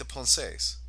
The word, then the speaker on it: pensées: Yeah, like the pensées, if I pronounce that right. – You did.